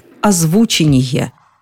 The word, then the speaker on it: озвучение: Орфоэпический словарь под редакцией Аванесова приводит единственный вариант – озвучение. Озвучение фильма. Нет в этом словаре никакого озвучивания, и тем более нет озвучания. Озвучение. И только так.